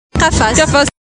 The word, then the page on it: Qafaz: arabe classique